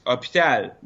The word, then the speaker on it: hôpital: No, it's a French thing because we don't pronounce the H in French. So we just skip it. Even like hospital in English, you pronounce the H, right? – Of course. – And in French, it's hôpital, but the H is there, but we skip it.